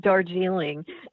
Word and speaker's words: Darjeeling: So forgive me for my ignorance and lack of knowledge, but I think it's page 8 when he sipped his dark jeering. Am I saying that right? And then put his mug back into a coaster. Am I saying that yes. right? – Darjeeling.